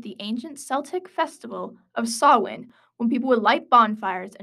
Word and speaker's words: Samhain: I'm going to find out how to pronounce this. Celtic. No, get that word I got. Samhain. – Samhain here.